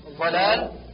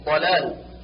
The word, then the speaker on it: ضلال: بالضاد ضاء مثل ظلال، والصواب ضلال، ومثل ولا الضالين، والصواب ولا الضالين.